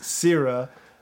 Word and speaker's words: Sira: Sira, pronounced Sira.